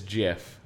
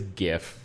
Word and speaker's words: GIF: And would read the G as a hard G. And I understand that the guy who invented the format says that it is GIF, but he is also wrong.